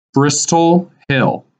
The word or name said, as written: Bristol Hill